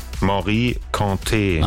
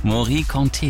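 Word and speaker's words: Mory Kanté: Wie wird's ausgesprochen? – Morikante. – Achso, guck mal. Es war also falsch. Morikante, Jeke, Jeke.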